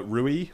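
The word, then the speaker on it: Rulli: The Villarreal goalkeeper, I'm probably going to butcher his name, but Rui, I think, or Ruji, maybe. – If we're doing Argentinian pronunciation, it'd be more like that. Yeah. The double L, but yeah.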